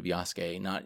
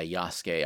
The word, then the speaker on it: Yasuke: Your pronunciation was quite good of Yasuke. It's spelled like Yasuki, but yeah, Yasuke.